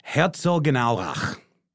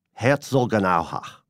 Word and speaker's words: Herzogenaurach: It's Herzogenaurach.